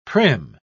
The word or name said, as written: prim